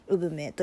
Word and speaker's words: 姑獲鳥: 骨格鳥って書いて産めと読むようになったのではないかという推測がされます、うん、では本題の産め